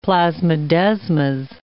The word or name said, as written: plasmodesmas